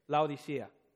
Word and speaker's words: Laodicea: Is that how you pronounce it? Laodicea. Thank you.